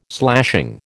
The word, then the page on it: slashing: American English